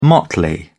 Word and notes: motley: British English

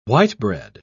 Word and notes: white-bread: British English

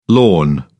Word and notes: lorn: British English

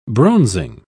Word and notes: bronzing: British English

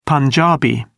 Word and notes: punjabi: British English

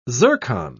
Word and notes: zircon: British English

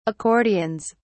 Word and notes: accordions: British English

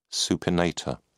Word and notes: supinator: British English

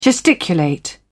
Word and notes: gesticulate: British English

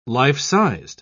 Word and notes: life-sized: British English

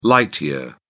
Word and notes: light year: British English

